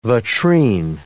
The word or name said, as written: vitrine